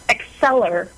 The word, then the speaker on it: Accelir: So, uh, how do we pronounce the, the company name? – Accelir, like accelerating growth.